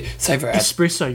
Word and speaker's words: espresso: Espresso, you got to say it right. People get angry when they say it. There's no X in there. Espresso. – Espresso. – It's not espresso.